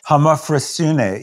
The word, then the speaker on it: homophrosyne: It feels to me like what you refer to a lot in an Odyssey of Homophrosyne. Is that how you pronounce it? – Homophrosyne, yeah, Homophrosyne.